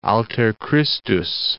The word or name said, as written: alter Christus